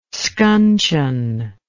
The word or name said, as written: scuncheon